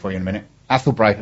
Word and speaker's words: Athelbrae: And their Twitter handle is Athelbray LTD. That is spelt A-T-H-E-L-B-R-A-E LTD. A-T-H-E-L-B-R-A-E LTD. Athelbray Limited.